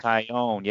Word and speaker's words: Taillon: Chad Cole, Stephen Brault, maybe Jameson Taillon. I can't even say his name. – Oh, Tyone. Yeah, he's another Tyone.